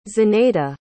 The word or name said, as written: zenaida